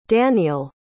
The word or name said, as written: danial